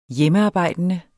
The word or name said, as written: hjemmearbejdende